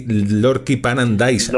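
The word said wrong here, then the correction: Lordkipanidze: Lorkipaniche. Escribe Z, pero se pronuncia Che.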